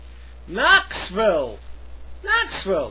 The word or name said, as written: Knoxville